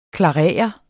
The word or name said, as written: klarere